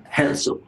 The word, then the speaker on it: Herzl: El fundador fue un periodista austro-húngaro de origen judío llamado Teodoro Herzl. Espero estar pronunciando bien. – Herzl, ok.